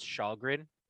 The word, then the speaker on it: Kallgren: So he was, so we're getting, and he spells his name like K-A-L-L-G-R-E-N, and there's like an accent in there somewhere, and it's like pronounced Shalgren, but it's with a K, so very many Shawl jokes today.